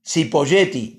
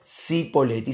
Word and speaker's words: Cipolletti: Cipolletti, no, no, no, Cipoletti, se escribe Cipolletti, pero es una palabra italiana, Cipoletti se pronuncia.